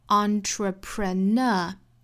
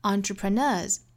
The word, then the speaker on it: entrepreneur: 那这个词我们要记好是读成 entrepreneur，那后面加的这个 s 应该读浊音的 z 的发音 entrepreneurs，但是也不要读得过重哈，不是 entrepreneurs，应该是 entrepreneurs。